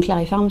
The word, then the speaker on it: Claripharm: Donc Clarifarm, c'est comme Clary, c'est comme pharmacie.